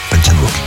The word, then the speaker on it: Park Chan-wook: Park Chan-wook. – Plus dur. – Park Chan-wook.